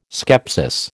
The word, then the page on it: skepsis: American English